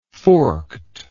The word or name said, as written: forkedness